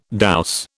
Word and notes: douse: British English

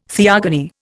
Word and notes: theogony: Indian

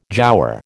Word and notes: giaour: American English

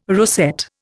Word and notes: rosette: Indian

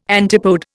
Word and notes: antipode: Indian

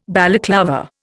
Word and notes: Balaclava: Indian

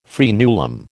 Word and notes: fraenulum: American English

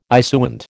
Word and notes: issuant: American English

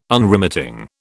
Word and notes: Unremitting: British English

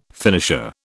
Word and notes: finisher: British English

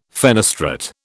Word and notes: fenestrate: British English

